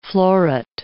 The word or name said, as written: floret